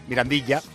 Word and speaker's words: Mirandilla: En Carranza, bueno, en el nuevo Mirandillo. Jolín, ¿cómo estoy? Mirandilla. Cádiz Rayo Vallecano, eso lo he dicho bien.